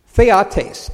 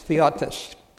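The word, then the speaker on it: theotes (θεότης): Now, the word Godhead is theotes, T-H-E-O-T, long E-S, theotes.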